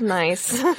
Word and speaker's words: gneiss: It's spelled G-N-E-I-S-S. So you wouldn't think it's pronounced nice, but it's from Scotland.